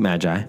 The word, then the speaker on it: Magi: How do you say that word? – Magi.